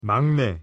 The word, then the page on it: 막내: Korean